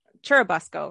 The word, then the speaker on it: Churubusco: So Busco is short for the town Churubusco, right? Is that how you say it? – Yep.